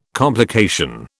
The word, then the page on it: complication: British English